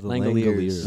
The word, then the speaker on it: Langoliers: Langoliers.